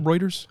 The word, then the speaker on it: Reuters: So this says that the initial report came from Reuters. Did I pronounce that right? Is it Reuters? – Yeah.